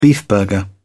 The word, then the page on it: beefburger: British English